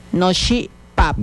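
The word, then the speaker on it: noxi' páp: No, she. Pap.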